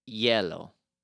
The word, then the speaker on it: hielo: And ice is yellow. – Yellow. – And it's spelled with H. – H-I-E-L-O? – Perfecto.